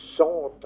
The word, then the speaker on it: sunt: On prononce sont en français.